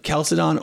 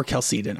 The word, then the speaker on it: Chalcedon: Apostles' Creed, Nicene's Creed, Athanasian Creed, Chalcedonian. Is that how you say it? – Chalcedon or Chalcedon are both okay.